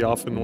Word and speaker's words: often: I know her cousin solely by a Colts sweatshirt that she often wears. Can we talk about the word often really quick? And we're not supposed to pronounce it with the T, right? – Correct.